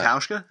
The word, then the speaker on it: Hauschka: So after my Shane Vereen pick, we're looking at Steven Hoshahawken. Is that right? – Steve Hauschka, the kicker? – Hauschka. Really? Interesting. Yeah. I've been pronouncing it wrong for years.